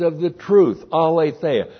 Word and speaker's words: aletheia: And we finished 3 John on Thursday night, and throughout that little tiny epistle is the word aletheia. A-L, long E-T-H-E-I-A. Aletheia.